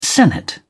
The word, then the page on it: senate: British English